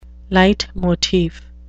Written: leitmotif

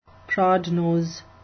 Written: prodnose

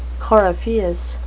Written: coryphaeus

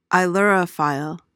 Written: ailurophile

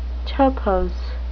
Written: topos

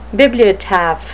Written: bibliotaph

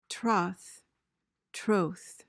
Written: troth

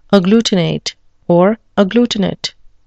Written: agglutinate

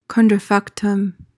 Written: contrafactum

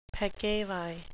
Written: peccavi

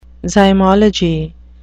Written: zymology

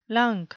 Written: lunk

